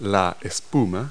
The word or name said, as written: foam